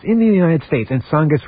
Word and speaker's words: Tsongas: Anyway, one of the more heated arguments came when Bill Clinton said that Paul Tsongas, besides having a silent T to start his name, well, if he were president, he would allow construction of hundreds of nuclear power plants.